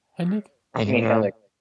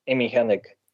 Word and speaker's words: Hennig: Amy Hennig. – Yeah. Say that again. – Amy Hennig.